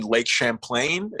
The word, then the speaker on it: Champlain: I might have to correct you on the pronunciation, though. I think you mean Lake Champlain? Is it? Yeah. Champlain. Yeah, yeah.